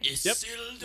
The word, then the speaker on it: Isildur: And then his son, Isildur. Is that, am I saying that right? – Isildur. Yep. Nailed it. Isildur.